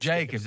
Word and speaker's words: Jacobs: Now, you might say Jacoby. Is that how you say his name? The running back from the Raiders? – Jonathan. – Jacobs. – Jacobs. – Jacobs, that's it.